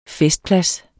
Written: festplads

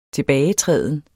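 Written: TILBAGETRÆDEN

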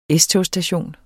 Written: S-togsstation

